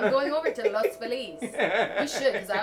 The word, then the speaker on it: Los Feliz: It's really, people say Los Feliz because Los Angeles for the Los, right. – And then Feliz because that's how someone pronounced it a long time ago.